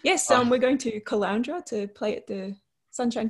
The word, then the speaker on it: Caloundra: Is it called the Caloundria? – Caloundra.